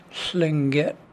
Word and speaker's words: Tlingit: Only Vonda pronounced the name correctly. – That is Tlingit.